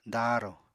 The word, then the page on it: daarò: apatani